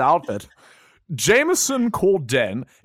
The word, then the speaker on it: Corden: Why did you say it like that? – Corden. Is that not how you say his name? – No, I think it's just Corden.